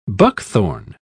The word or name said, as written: buckthorn